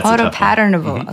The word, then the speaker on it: photopatternable: And that makes it so we can convert any nanoparticle into what is called a photopatentable ink. – And what does this photopatentable, am I saying this right? – Patternable. – Yeah, photopatternable. Okay, ink.